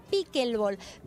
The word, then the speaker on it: pickleball: el pickleball.